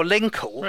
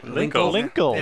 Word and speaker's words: Linkle: Oh, Linkle. – Right, Linkle.